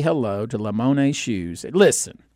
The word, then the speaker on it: Limone: Just Google Lamone Shoes. It's spelled L-I-M-O-N-E. It's Italian.